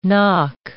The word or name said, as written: nock